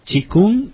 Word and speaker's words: Qigong: como el Qigong y Tai Chi Chuan.